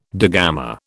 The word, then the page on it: digamma: British English